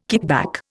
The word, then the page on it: kickback: Indian